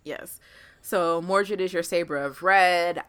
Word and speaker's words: Mordred: Because I haven't heard her name Mordred. – Mordred.